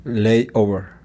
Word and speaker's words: layover: La pronunciación fonética es L-E-I-O-V-R. Nota que no hay vocal entre la V y la R y siempre es por una razón. – Layover.